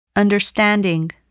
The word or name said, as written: understanding